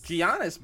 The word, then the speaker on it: Giannis: It's like Giannis? – Giannis, bro.